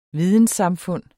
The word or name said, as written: videnssamfund